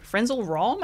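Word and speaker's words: Frenzal Rhomb: Friends All Wrong. They're huge. Yeah, they were huge for me back in the day. – Because I know who they are, but I've never been confident in pronouncing the name. – And you nailed it.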